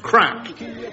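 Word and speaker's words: craic: It's spelt crake, but it's pronounced crack.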